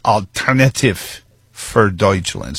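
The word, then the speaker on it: Alternative für Deutschland: I'm told it's Alternativ für Deutschland, spelled like, and I imagine it's one of those words that came along after the Dutch, the Germanic languages had all metastasized.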